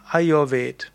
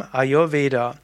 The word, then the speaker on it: Ayurveda: Und die, die Hindi-Aussprache wäre eben Ayurved. Und Ayurved ist also die Hindi-Aussprache, und wenn man dann irgendwo mischt Sanskrit mit Hindi, wie es in anderen indischen Sprachen üblich ist, dann sagt man eben Ayurveda.